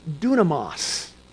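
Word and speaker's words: dunamos (δύναμις): it's the Greek word pronounced dunamos. Dunamos. Now, dunamos, that might in your mind sound a little bit like the word dynamite.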